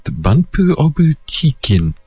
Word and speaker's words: BUMP OF CHICKEN: And they pronounce that Bunpu Obu Chicken.